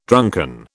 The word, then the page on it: drunken: British English